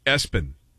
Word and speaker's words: ESPN: At number three, it's spelled ESPN, and it's pronounced Espen.